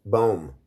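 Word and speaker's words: Böhme: Uh, then Daniel Leeds uh, wrote uh, the Temple of Wisdom in 1677 that was largely pra- plagiarized from Jacob Boham Bomim? – Boom. – Boom. Is that how that's pronounced? I think the H is usually silent.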